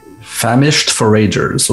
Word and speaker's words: Famished Forager: Famished Forager, qu'on prononce comme ça? – Famished Foragers, ouais.